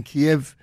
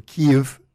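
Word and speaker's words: Kyiv: We're in St. Petersburg, which is way up north, but in Kiev, or Kiev, as they pronounce it these days, in Ukraine, they're about to have a spate of minus 30.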